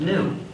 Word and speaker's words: gnu: You see, GNU is the most humor-charged word in the English language, used in countless wordplays, because according to the dictionary, the G is silent and it's pronounced new. So every time you want to write the word new, instead of spelling it N-E-W, you can spell it G-N-U and you've got a joke.